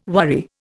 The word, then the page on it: worry: Indian